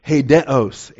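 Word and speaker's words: Hedeos (ἡδέως): Hedeos, H-E-D-E-O-S, the long E followed by the short E, Eta, Delta, Epsilon, Omega, Sigma, beginning with the rough breather, which we often transcribe with the H in the English, H-E-D-E-O-S, the long O, the Omega. So it's Hedeos.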